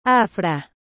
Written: afra

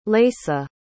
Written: leisa

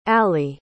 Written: allie

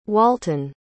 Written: walten

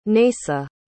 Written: Neysa

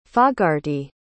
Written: fogarty

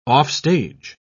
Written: offstage

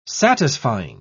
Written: satisfying

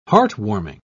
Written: heartwarming

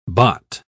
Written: Bott

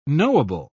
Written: knowable

